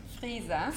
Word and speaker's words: Frieser: Frieser. – Frieser, oh my word. – Frieser, yeah, like the freezer.